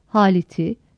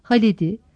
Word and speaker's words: Halit'i: Ancak kelimeler Ahmet'i, Halit'i, Mehmet'i, Murat'ı, Recep'i, Gaziantep'i, Sinop'u, Zonguldak'ı şeklinde telaffuz edilmez. Ahmet'i, Halid'i, Mehmet'i, Murad'ı, Recep'i, Gaziantep'i, Sinop'u, Zonguldak'ı şeklinde telaffuz edilir.